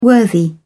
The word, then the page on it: worthy: British English